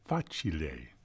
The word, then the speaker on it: facile: which is the sonata facile. Yeah, this has nothing to do with Christmas Story. Facile is how it's pronounced in Italian.